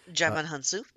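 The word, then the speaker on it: Djimon Hounsou: Digimon Hunsu.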